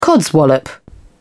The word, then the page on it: codswallop: British English